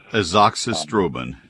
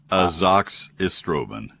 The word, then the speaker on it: Azoxystrobin: I can't pronounce it, the last part of it is strobin. – Azoxystrobin. – Yes. – Azoxystrobin. Yeah. – Azoxystrobin. Okay.